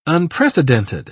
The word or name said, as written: migration